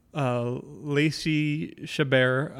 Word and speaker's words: Chabert: Lacey Chabert, I believe is how you pronounce it.